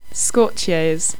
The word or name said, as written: Scorchios